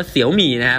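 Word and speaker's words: Xiaomi: เสี่ยวมี่นะ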